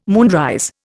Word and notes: moonrise: Indian

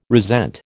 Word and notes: resent: American English

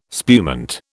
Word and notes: spumante: British English

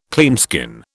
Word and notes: cleanskin: British English

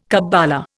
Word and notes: kabbala: Indian

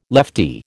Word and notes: leftie: American English

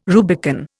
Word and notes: Rubicon: Indian

